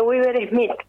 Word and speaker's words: Weaver: Tiene 21 años, es conocido como el pequeño gigante y él tiene síndrome de Weber. ¿Así se pronuncia? – Síndrome de Weaver-Smith se llama la enfermedad.